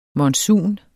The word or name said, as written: monsun